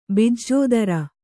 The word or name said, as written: ಬಿಜ್ಜೋದರ